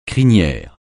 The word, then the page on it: crinière: francais